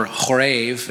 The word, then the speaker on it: Horeb: came to Horeb, or Horev, as it's pronounced in the Hebrew, the mountain of God.